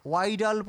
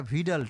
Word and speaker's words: Widal: এনারা একটা পরীক্ষা করেন, যে পরীক্ষাটাকে উইডাল পরীক্ষা বলে, অনেকে উচ্চারণ ভুল করে এটাকে ওয়াইডাল পরীক্ষাও বলে, WIDAL, কিন্তু উচ্চারণটা ভিডাল টেস্ট।